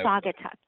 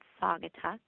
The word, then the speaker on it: Saugatuck: Saugatuck. You pronounce it Saugatuck.